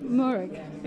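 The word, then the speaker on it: Mooragh: We were having a bit of a debate about Murrick, how you actually pronounce with a K, isn't it? Murrick Park. – Murrick.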